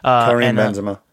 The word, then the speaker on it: Benzema: Is that how you say his last name? – Karim Benzema.